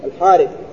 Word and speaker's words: الحارث: وإن كانت تكتب هكذا الحرث لكن تنطق إيه؟ الحارث.